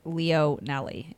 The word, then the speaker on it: Leonelli: People butcher my last name and it's Leonelli. It's just like phonetically. Phonetic. But they say like Leonely. I'm like, no.